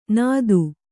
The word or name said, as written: ನಾದು